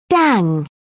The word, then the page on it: dang: British English